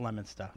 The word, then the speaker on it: Leominster: L-E-O-M-I-N-S-T-E-R. – Oh, that's a T. Wow. Leominster. Right? I don't know. Leominster? – She's close. She's getting close. – The correct way to pronounce it is Leominster.